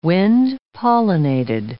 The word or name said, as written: wind-pollinated